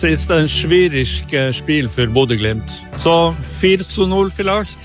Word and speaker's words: Bodø Glimt: Ja, wie spricht man dieses Bodø Glimt eigentlich aus?